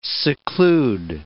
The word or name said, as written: seclude